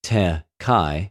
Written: τε...και

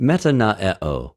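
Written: μετανοέω

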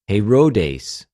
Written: Ἡρῳδης

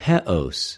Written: ἑως

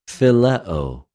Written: φιλεω

